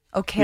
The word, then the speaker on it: Okereke: Okereke. – Yeah, think carrot cake.